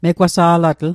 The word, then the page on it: megwasa'latl: Mi'gmaq